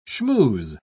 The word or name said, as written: schmooze